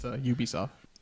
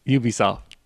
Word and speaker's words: Ubisoft: It's Ubisoft. – Ubisoft.